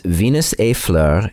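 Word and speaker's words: Venus et Fleur: That's Venus A Fleur, and I'll spell that for you. It's Venus, V-E-N-U-S, and then the French word A, which means and, so E-T, Fleur, which is the French word for flower, F-L-E-U-R.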